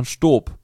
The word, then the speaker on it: Staub: Wir hatten ja hier, oder wir haben auch hier im Büro von der Firma, ich mache jetzt mal unbezahlte Werbung hier, das wird ausgesprochen Stob, Staub geschrieben.